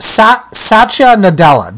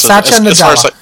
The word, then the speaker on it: Satya Nadella: So, and the new guys, by the way, it's Satya Nadella. Did I pronounce that right? – I think so. Yes, Satya Nadella.